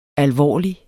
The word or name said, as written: alvorlig